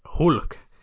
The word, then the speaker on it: Hulk: Nästa namn du får säg dina tankar om är Hulk, eller Huik som Elisa Palha i SVTs VM-studio 2014 fick oss att börja säga att han uttalas så.